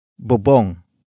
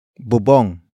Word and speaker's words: bubong: And that object is bubong. Bubong. Bubong. Bubong is spelled as B-U-B-O-N-G.